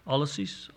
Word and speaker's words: Ulysses: There's one captain, Ulysses is his name. I'm not sure how you say that correctly. Ulysses, there you go.